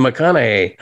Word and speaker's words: McConaughey: McConaughey.